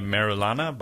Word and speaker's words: Merulana: Brutto on Via Merulana by Carlo Emilio Gada.